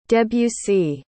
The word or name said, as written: debussy